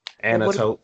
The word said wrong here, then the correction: Antiope: no, they say it in the movie. It's Antiope.